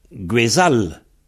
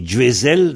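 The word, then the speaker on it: gwezhall: Donc, normalement, en KLT, on devrait dire Guézal, ce qui prononce le Z. Mm-hmm. Ben, effectivement, on dit Guézal et Guézel dans certains endroits. Par exemple, le pays Fanche a comme prononciation, là, Guézel. On prononce bien le Z, quoi, hein, Guézel. Mais dans le Trégor, le Z est devenu Je, dont on dit Guéjal, bah, c'est vrai que ze et je, c'est assez proche, hein.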